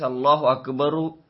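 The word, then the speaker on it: اللهُ أكبرُ: However, reciting with pesh, that is Allahu Akbaru, is better than reciting it with zabar, which means Allahu Akbara.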